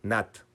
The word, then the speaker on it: Nantes: Es que Nantes se pronuncia Nat, pero si dices Nat, pues no te va a entender nadie. O sea,